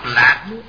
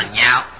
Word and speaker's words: nhạo: Chữ này đọc giọng cao, không đọc lạc mà đọc là nhạo, nhạo là ưa thích, hoan hỷ ưa thích, tin ưa thích.